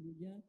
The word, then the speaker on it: Le Guin: par les Américaines dans les années 70, donc je parlais parmi les Sargent, mais bon, il y a évidemment Ursula Le Guin, ou Le Guin, comme certains le prononcent, puisque le nom était d'origine française,